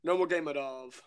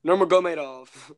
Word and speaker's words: Nurmagomedov: The notorious Conor McGregor versus the Eagle, Khabib Nurmagomedov. I said that all wrong. I butchered his name. Nurmagomedov.